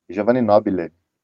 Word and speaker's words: Giovanni Nobile: O Nobili, como é que fala o seu nome? É, Giovanni Nobile. – Ah, Giovanni Nobile, que é um jornalista, um escritor que está lançando um livro chamado Enquanto o Resto Sufoca, que sai pela Ópera Editorial.